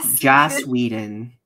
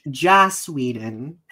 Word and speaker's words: Joss Whedon: Did you say his name right? Joss? – Joss Whedon. Did I say Josh? Joss Whedon. – Yes.